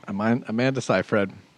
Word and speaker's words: Seyfried: Amanda Seyfried. We checked with Stephen Colbert how to make sure we pronounced her name correctly.